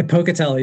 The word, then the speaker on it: Pocatello: I spell I pronounce that right, correct? Uh, Pocatello, yes. Pocatello, Idaho.